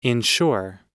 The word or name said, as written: inshore